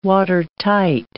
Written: watertight

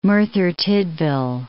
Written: Merthyr Tydfil

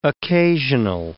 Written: occasional

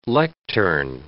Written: lectern

